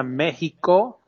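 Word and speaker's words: México: And actually the X is pronounced as a J. So it's Viva Mexico.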